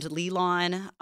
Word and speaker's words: Lilan: Please find me on Instagram at your friend Lilan. That's how you say my name, and how you spell it is Lilan, not Lillian, as it's so often spelled as.